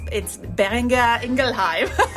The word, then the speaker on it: Boehringer Ingelheim: Boehringer Ingelheim. – Boehringer Ingelheim. – Well, it's a German name, so it's Boehringer Ingelheim.